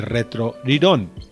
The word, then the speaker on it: Retro Rirón: Retro Rirón. Retro Rirón, que se escribiría Retro Lilón, pero se pronuncia Retro Rirón por las condiciones del habla o la pronunciación japonesa,